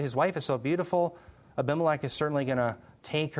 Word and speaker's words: Abimelech: I can say it better in Hebrew. It's Avi Melech. It's father king. Melech is king. Avi is father. But Abba, how do you say it in English? Abimelech, thank you.